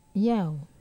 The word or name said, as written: yau